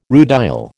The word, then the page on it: rutile: American English